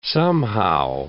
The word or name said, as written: somehow